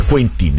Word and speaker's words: Quentin: Intanto mi ha mandato un altro messaggio Quentin, lo avevo letto alla francese Quentin, invece mi dice che si scrive come si legge, non come si scrive. Dunque Quentin, il messaggio Quentin di Quentin Tarantino, che era riferito a quel paragone, sì, Mastantuono-Ilicic.